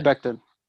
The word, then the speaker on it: Becton: Mekhi Becton.